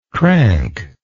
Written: crankness

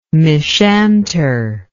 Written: mishanter

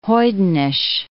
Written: hoydenish

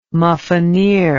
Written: muffineer

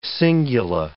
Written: cingula

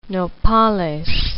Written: nopales

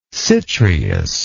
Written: citreous

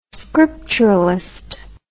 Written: scripturalist

